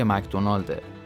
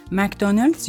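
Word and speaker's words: McDonald's: همونطور که در اپیزود 17 هم گفتیم، مک‌دونالدز یا با تلفظ ما ایرانی ها مک‌دونالد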